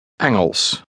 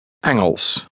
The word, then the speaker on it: Engels: Engels. Eng. Eng. Engels.